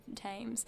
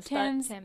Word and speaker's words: Thames: And she died because she caught a cold from posing in this freezing cold bath full of dirty water from the Thames. I've pronounced that incorrectly. – Thames.